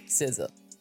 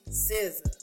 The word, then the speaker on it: SZA: you properly say her name? SZA. – SZA. SZA. – like hood, uh, how you would say scissors the hood way.